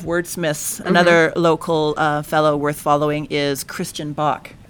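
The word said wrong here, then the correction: Bok: Christian Bach, whose last name is spelled B-O-K. Well, I think he pronounces it Book. My bad. Christian Book.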